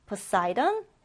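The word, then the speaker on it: Poseidon: 영어 발음으로는 헤이디스, 포사이돈, 제우스.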